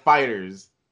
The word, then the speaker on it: FighterZ: And I pronounce it like fighters. C at the end. It's just like that. You don't have to say Z. It's fighters.